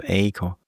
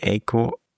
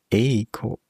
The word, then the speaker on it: Eiko: Naja, sprecht mal da, wo ihr seid, Aiko aus mit einem langen E und guckt mal und beobachtet eure Stimme, ob ihr in so ein leichtes I kippt, wenn ihr ein langes E ausspricht, so Aiko. So spricht man diesen Namen jedenfalls aus. Langes E, Aiko.